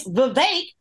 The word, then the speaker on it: Vivek: It's Vivek like cake.